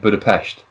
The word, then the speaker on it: Budapest: I mean, I've got one thing, which is actually, when you go there, don't be shocked if people are calling it Budapest, because that's how it's pronounced. – Proper pronunciation. But I've heard a lot of people say, from the UK and from America, say Budapest.